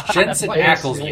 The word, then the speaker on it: Jensen Ackles: No, that's Jason Aikman or whatever his name is. – Jensen Ackles, you fucking... I'm about to go stand just so for Brandon to not be here, so... Mispronounced Jensen Ackles, dare you.